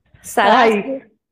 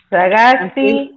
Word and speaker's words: Sagasti: Sagaski. Hofhauser. Yeah, Hofhausler. – Ay. – Hausler. – Tengo, tengo que leerlo, tengo que leerlo. – ¿Cómo es? Sagasti.